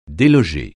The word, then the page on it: déloger: français